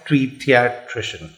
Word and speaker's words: Tweediatrician: Hashtag Tweediatrician. Is that right? Yep. Okay. That's exactly right.